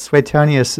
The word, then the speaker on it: Suetonius: I've also heard it pronounced Suetonius, but Suetonius is what I heard an Englishman say. So, of course, it's a much more sophisticated pronunciation.